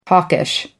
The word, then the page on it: hawkish: American English